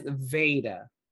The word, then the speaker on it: Veda: Oh, it is, is Veda. V-E-D-A. – yeah, Veda. That's mm-hmm. how they, according to what I just looked up, it, that's how it's pronounced.